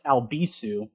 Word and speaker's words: Albisu: Like your yeah, find me on Twitter um, at Alex Albisu.